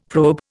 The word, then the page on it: probe: Indian